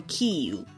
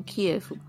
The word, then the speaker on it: Kyiv: 那首先第一个字呢，念作 Kyiv，这个字呢就是乌克兰的首都基辅，念作 Kyiv 是这一阵子才有的事情。 – 嗯，因为它原本好像是念 Kiev。 Kyiv 的念法好像是比较接近乌克兰语的发音。